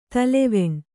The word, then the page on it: ತಲೆವೆಣ್: taleveṇ